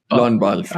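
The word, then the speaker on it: Lorne Balfe: Lon Balf, right? E is silent, right? E's are silent.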